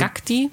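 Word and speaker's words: Gákti: Ja, ich habe Jokti oder ich weiß nicht, wie das A mit diesem Akzent ausgesprochen wird, aber ähm, Jakti oder also, Gakti. Das gefunden. ist wie ein Ä, wie, wie das norwegische A gesprochen.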